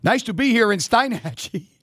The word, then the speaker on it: Steinhatchee: Apparently, I've been mispronouncing it for like the last 17 years. But it's but anyway, I saw it last night on Weather Channel. It was Steinhatchee.